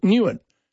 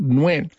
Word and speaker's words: Nguyen: However, in Australia, most people pronounce it Nguyen. Now, we look at it, and it's got a U and a Y, and we just think, oh, well, that, you know, it looks a bit strange. That's how we'll do it. But it's not pronounced Nguyen. It's pronounced Nguyen.